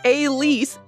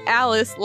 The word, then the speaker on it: Alice: He and his girlfriend Alice pronounced, thank you, Terrence, pronounced Elise, not Alice like it looks.